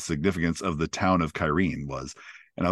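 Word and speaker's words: Cairhien: was Kyrene, or if you read the books, Karhain. So I did not know that you pronounced it Kyrene, but it was towards the end of this episode, and it was when they were going.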